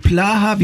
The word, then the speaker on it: Plaha: Helmut Plaha, wie spricht man das? Ja, relativ, ja, ne? – Du machst es richtig. – Ja? – Ja, ja, der Name Plaha kommt eigentlich aus dem Tschechischen.